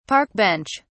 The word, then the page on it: park bench: British English